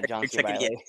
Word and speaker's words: John C. Reilly: God, I think his name's John C. Reilly. I'm butchering that. It's the guy who was in Step Brothers. – Yeah, John C. Reilly.